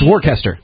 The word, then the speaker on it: Worcester: W-O-R-S-T. – It's Worcester. – It's Worcester. There's no H, but it's like Worcester, and it somehow turns into Worcester.